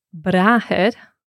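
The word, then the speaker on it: bràthair: And the word for brother is brahir. – Brahir. – And that's got an accent on the A, just like mahir. – So we've got to emphasise it. Yeah, brahir.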